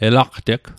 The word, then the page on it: elaqteg: Mi'gmaq